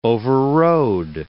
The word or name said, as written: overrode